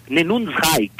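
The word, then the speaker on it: Neunundneunzig: Nenunsreich, quer dizer, 99 Luftballons.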